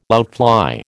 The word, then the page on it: outfly: American English